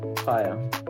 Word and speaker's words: Fia: How do you say it? – Fia. – Fia. Not Fia. Fia.